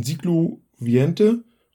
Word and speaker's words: Siglo XX: Und wie ich jetzt gelernt habe, könnte man das auch aussprechen. Siglo Viente und das würde dann Spanisch fürs 20. Jahrhundert stehen.